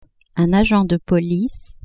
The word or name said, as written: agent de police